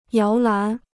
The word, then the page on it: 摇篮: yáo lán